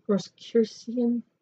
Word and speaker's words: Rosicrucian: She also acquired a vast and uncanny, this is like their words, uncanny knowledge of Masonic, I'm going to say this wrong, Roscurian, Rosicrucian, hmm, I knew I said it wrong, ritual and symbology.